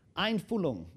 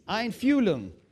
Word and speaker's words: Einfühlung: But the real sense of it in English comes from a German word, which is Einfühlung, Einfühlung. You have to pronounce the middle. Einfühlung.